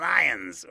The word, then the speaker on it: Lyon: Okay, how do you say that? – Yeah, ingens concelebratum est eius in cultum et honorem funus. Lugduni in Lyons or Lyon. – Lyon. Lugduni is the Roman word.